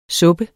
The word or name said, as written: suppe